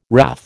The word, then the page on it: wrath: American English